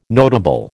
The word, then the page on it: notable: American English